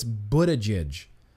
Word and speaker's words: Buttigieg: I'm pretty sure it's Buttigieg.